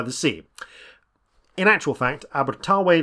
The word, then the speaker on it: Abertawe: In Welsh, Swansea is pronounced Abertawe,